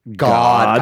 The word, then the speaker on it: Gad: Except for it's pronounced God.